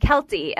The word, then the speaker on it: Keltie: And I said, Kelty. And I go, K-E-L-T-I-E, to spell it.